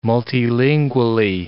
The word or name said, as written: multilingually